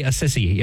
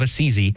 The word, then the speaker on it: Assisi: It's don't say a sissy. You know, St. Francis was not a sissy. So say St. Francis of Assisi. That's how you pronounce it.